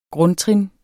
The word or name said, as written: grundtrin